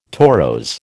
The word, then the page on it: torose: American English